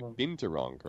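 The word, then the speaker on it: binturong: I think that's what you say, bingturong, right? – No, you said it right.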